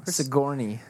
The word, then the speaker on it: Sigourney: Sigourney.